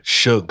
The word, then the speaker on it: Suge: Suge Knight.